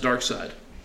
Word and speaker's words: Darkseid: It is pronounced Darkseid.